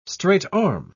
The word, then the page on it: straight-arm: British English